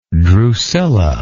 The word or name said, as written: drucilla